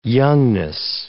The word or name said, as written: youngness